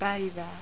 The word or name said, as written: paiva